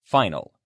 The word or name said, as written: final